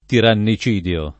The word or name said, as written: tirannicidio